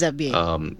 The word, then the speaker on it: Abyei: There's been a lot of border skirmishes, specifically, I think, in the region on the border between Sudan and South Sudan of, I hope I get the pronunciation right, it's Abyei. – Yes, it is Abyei.